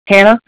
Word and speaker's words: Hannah: Hannah. H-A-N-N-A-H.